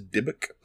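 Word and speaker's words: Dybbuk: It's and, oh yeah, I didn't want to, like, interrupt you, but I believe the actual pronunciation is Dibbuk, but I don't.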